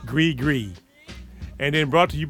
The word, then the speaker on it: Gris: Gree Gree, thank you for the pronunciation there, Mr. Matt, because I would have said gris. So I definitely would have fucked that up.